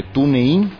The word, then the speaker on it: TuneIn: Hay que descargarse la aplicación de TuneIn, así se dice castizamente y se pronuncia en inglés, TuneIn.